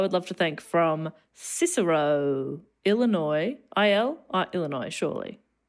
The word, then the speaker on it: Cicero: Cicero. That's in Chicago. That's how I knew how to pronounce it. If I've done it right, it's because of the musical Chicago.